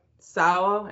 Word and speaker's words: Sawa: I noticed that Devin Sawa, am I pronouncing his last name correctly? – Yeah.